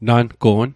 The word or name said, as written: nango'n